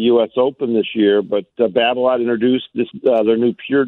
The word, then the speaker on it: Babolat: The new Babolat. Babolat? – Yeah, yeah. – Pure Drive? – Yeah, you got a Babolat.